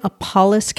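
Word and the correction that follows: nopales: cactus? – Nopalis. – Nopalis. Yes.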